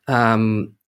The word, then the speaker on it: Cerpelloni: Because do you want the Italian pronunciation or the English pronunciation? So sometimes I say Cerpelloni, which doesn't, I don't identify with that. Cerpelloni, that's the Italian right pronunciation.